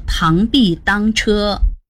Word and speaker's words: 螳臂当车: 但是如果看到的成语用的字是当，还是得读作螳臂当车。